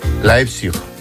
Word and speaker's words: Leipzig: Leipzig. ¿Lo he dicho bien? – Espera, qué importante es saber idiomas. – Tengo aquí a mi amigo que me lo dice. ¿Cómo se dice? – Leipzig. – Leipzig. Leipzig, ¿ves? – Leipzig, muy bien.